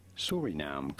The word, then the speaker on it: Suriname: How do you go about pronouncing its name? Suriname. – Oh, I was right the first time. – Suriname country in South America.